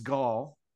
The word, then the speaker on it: Gaul: Edited by James Gaul.